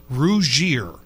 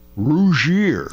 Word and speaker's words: Rougier: How do you pronounce it? Rougier. Rougier. – Yeah. – Okay. – It literally means to make red in French or to blush.